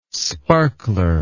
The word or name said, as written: sparkler